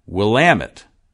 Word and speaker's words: Willamette: as an Oregon boy who grew up in the Willamette, like cat, he says. He says, it has its stress on the A, and it's a short A, pronounced as in cat. So, Willamette,